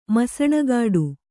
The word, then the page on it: ಮಸಣಗಾಡು: Kannada